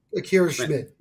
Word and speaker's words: Schmidt: Is this, speaking of goaltenders, assuming I say his name right, because I saw him in Utica a little bit, Akira Smead. I think I got his Schmidt. Name correct. – Akira Schmidt.